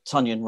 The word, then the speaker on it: Tonyan: We're seeing the tight end Robert Tonyan, rhymes with onion, catching the ball and getting a load of touchdowns, something that Jimmy Graham wasn't doing for Green Bay.